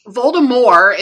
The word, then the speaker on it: Voldemort: Voldemort is actually the proper way to pronounce his name.